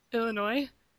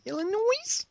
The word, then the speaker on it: Illinois: I thought I heard the S in there at the end. – No, there is no S in Illinois. – Illinois? – Well, there is in the print, but not in the pronunciation.